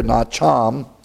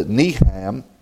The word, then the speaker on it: nacham: Here we have the unusual Hebrew word here, nacham, or it's a niham, that's spelled N-A-C-H-A-M.